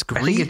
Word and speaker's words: Gris: It's not Gris, because that's just gross. That's gross. It's Gris.